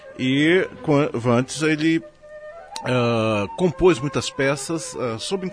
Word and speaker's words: Quantz: Se escreve Quantz, mas conselheiro de língua alemã, o Hermano Velta, me chamou a atenção de que o QU, no caso, aí se pronuncia quase como V, Quantz.